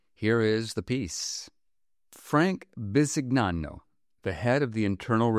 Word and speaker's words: Bisignano: And reader's note, the spelling of that last name is B-I-S-I-G-N-A-N-O, and for the purposes of this story, we're going to pronounce it Bisignano.